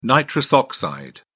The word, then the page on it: nitrous oxide: British English